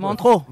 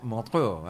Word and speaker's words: Montreux: Montreux, no lo sé pronunciar bien. Montreux. Montreux, ahí está. Sí, es en Suiza, pero es una palabra francesa.